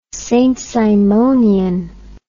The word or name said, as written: saintsimonian